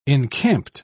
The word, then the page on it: encamped: British English